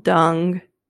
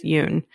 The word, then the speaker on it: Nguyen: It's spelled dung. And if I say dung, please stop me because it is Yuen.